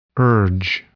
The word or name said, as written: urge